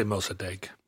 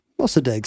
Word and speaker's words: Mossadegh: And when a man named Mohammad Mossadegh, I hope I'm saying that right, because I'm going to be saying it a lot, Mossadegh maybe? – Mm-hmm. Let's say Mossadegh. – Mossadegh seems better.